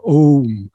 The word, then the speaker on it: Om: This is why you sometimes see Om spelt in English as A-U-M, but it shouldn't be pronounced as Aum. That is misleading. It is Om, because the O is the transition from R to U.